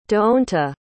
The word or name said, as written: deonta